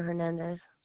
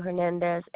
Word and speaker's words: Hernandez: It's Francisco and Tina Hernandez. – Spell that for me. – Francisco, C-I-S-C-O. My name is Tina, T-I-N-A. Last name Hernandez, H-E-R-N-A-N-D-E-Z. – Thank you. That's going to be F-R-A-N-C-I-S-C-O and Tina, T-I-N-A. Last name, H-E-R-N-A-N-D-E-Z.